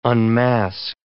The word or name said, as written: unmask